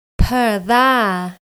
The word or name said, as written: Pur dha